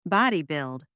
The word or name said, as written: body build